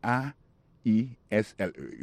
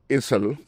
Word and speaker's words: aisle: Aisle là, c'est A I S L E. You prononce aisle. Li marqué Essel.